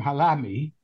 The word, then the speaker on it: Halahmy: And because he put the silent H in, we say Halahmy.